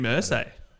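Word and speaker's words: Irsay: seen the headline from Colts owner Jim Isra? – Or Jim Ursay. Ursay.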